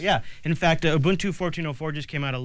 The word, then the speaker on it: Ubuntu: Ubuntu, yeah.